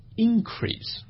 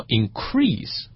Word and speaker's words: increase: 对，我们查字典的时候会发现它有两个发音方式，一个呢是把重音放前面叫 increase，另外一个呢是把重音放后面叫 increase。这个到底是为什么呢？